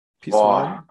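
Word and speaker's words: Pizjuán: Welche Mannschaft spielt im Ramon Sanchez Pizjuan? – Pizjuan. – P-I-Z-J-U-A-N.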